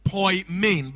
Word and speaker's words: poimen: It's pronounced poimen, but it's spelled P-O-I-M-E-N.